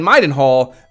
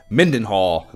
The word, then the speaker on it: Midenhall: had been calling Midenhall Mindenhall my whole fucking life. Mm. And there is no N in the first syllable, and kids are so dumb and oblivious.